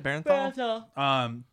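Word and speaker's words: Bernthal: I don't know how to pronounce his last name, Berthal, the Punisher? – Barenthal? – Barenthal, is that it? – Barenthal.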